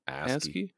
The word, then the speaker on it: ASCII: ASCII.